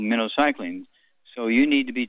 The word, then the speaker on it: minocycline: It's pronounced like the bait fish, minocycline, minnow, okay?